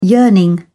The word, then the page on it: yearning: English; British English